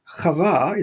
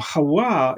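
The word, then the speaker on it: Chava (חוה): And we have Eve in the story of the Garden of Eden, and Eve is called the mother of all living, and Chava is how you pronounce Eve in modern Hebrew. Chava is a Jewish name today for a girl. Hawa is how you would have pronounced it in ancient times, and that's very similar to the Hebrew word for life, which is Chai.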